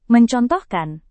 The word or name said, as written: mencontohkan